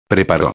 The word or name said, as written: preparo